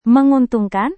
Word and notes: menguntungkan: Indonesian

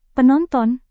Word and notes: penonton: indonesian

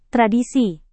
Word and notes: tradisi: Indonesian

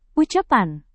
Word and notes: ucapan: indonesian

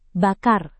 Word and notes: bakar: indonesian

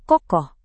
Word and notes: kokoh: indonesian

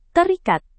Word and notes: terikat: indonesian

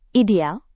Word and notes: ideal: Indonesian

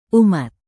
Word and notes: umat: indonesian

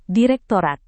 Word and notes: direktorat: indonesian

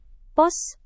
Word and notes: pos: Indonesian

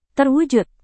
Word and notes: terwujud: Indonesian

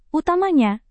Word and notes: utamanya: Indonesian

